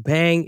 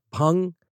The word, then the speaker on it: bhang: It's spelled B-H-A-N-G. In the U.S., a lot of people pronounce it as bang. It's actually pronounced pung.